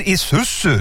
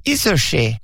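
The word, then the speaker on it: Isuzu: Alltså, du menar din isusu? – Det var ju det jag sa, isushi.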